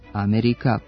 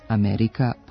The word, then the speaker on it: Amerika: Navedeni pridevi nisu usamljeni primjeri nenormiranog prenošenja akcenta na prvi slog, već istu pojavu prepoznajemo i u izgovoru imenica Amerika, politika, republika.